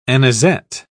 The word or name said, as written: anisette